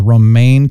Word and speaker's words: Romijn: You know, I mean, it's Romijn is Romaine.